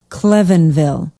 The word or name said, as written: klevenville